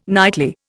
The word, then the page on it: nightly: Indian